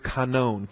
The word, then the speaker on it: kanon: The Greek word here for sphere, at least in the New American Standard Translation, the term that's translated sphere is the Greek word kanon, K-A-N-O-N, which is the long O omega.